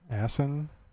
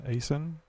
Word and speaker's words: Aisin: Okay, I looked up something here on the Aisin Warner. – Did you call me? – Yeah, exactly. Actually, it was a question. AW4 transmission. How do you pronounce that? A-I-S-N, Aisin? – Aisin, yeah.